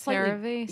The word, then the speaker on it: CeraVe: Is that how you pronounce it? CeraVe? – I don't know. It's a similar cleanser, but it's CeraVe. Slightly, CeraVe. – Yeah. CeraVe. – Yeah, there's a little accent.